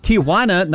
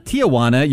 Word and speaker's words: Tijuana: It's Tijuana, not Tijuana. You mispronounced it wrong twice recently.